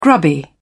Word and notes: grubby: British English

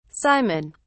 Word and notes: simon: British English

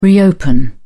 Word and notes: reopen: British English

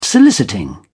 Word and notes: soliciting: British English

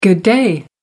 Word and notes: good day: American English

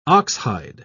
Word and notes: oxhide: British English

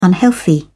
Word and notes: unhealthy: British English

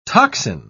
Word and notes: tocsin: British English

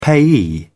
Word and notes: payee: British English

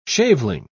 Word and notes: shaveling: British English

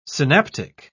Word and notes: synaptic: British English

